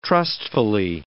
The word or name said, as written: trustfully